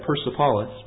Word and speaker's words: Persepolis: On one of the pilasters at Persepolis, and that Iranian friend of mine, by the way, pronounced that Persepolis, but in one of the pilasters at Persepolis, that very event seems to be recorded.